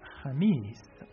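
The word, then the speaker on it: jamis (خميس): Ha, mi, jamis, jamis.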